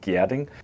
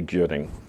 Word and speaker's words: Gjerding: So it's pronounced Gjerding, but most English-speaking people will say Gjerding. It's just the GJ is a little hard.